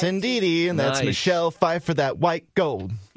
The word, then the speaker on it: Pfeiffer: It's Michelle Pfeiffer.